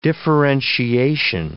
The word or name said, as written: differentiation